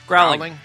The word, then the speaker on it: Groening: Groening.